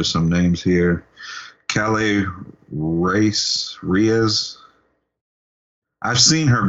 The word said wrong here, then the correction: Kaylee: And I think it's pronounced Kaylee. – Kaylee Reese. Okay.